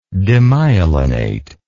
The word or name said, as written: demyelinate